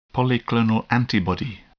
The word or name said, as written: polyclonal antibody